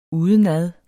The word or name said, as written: udenad